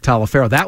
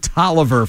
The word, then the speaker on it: Taliaferro: Except his name wasn't pronounced Mike Taliaferro. That's the way it looked on the back of his jersey. If you looked at his jersey, it said Taliaferro. – Yeah. – But he actually pronounced it Taliaferro for whatever reason.